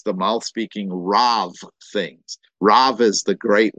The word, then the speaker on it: Rav: The Rav is the rabbi, the rabbi. It's R-A-B in the original language, Rav, but it's pronounced, the B is pronounced like a V in Hebrew and Aramaic.